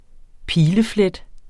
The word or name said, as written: pileflet